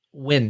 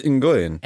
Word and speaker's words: Nguyen: Is that how you pronounce it? – Yes. – Okay. – That's one of the few I got. – Win. – Yeah. – I thought it was Nguyen. – N-G-U-Y-E-N, unless I've been Americanizing it the whole time, which is absolutely possible. – No, I've taught students with that last name, and they have pronounced it that way, so I assume that everybody with that last name must pronounce it that way.